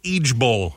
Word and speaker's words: IJBOL: It's pronounced Eejbowl instead of LOL. You say Eejbowl for I just burst out laughing.